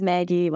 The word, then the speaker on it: Maggie: So my human name is Maggie.